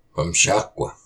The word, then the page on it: Pumsháq: Y’all walk!